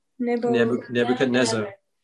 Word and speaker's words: Nebuchadnezzar: Nebuchadnezzar.